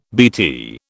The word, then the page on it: BT: British English